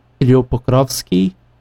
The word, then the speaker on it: Kirill Pokrovsky: I will butcher the pronunciation, I'm just sure, but I believe he was called Kirill Pokrovsky, or something along those lines.